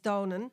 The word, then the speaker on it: Domnann: Anyway, Mark Williams pointed out the similarity between his name, which is spelled D-O-M-N-A-N-N, or A-N-N, I suppose, yeah, the English alphabet, but pronounced Donan, with a name that was used for the Tuatha Dé in the 10th century.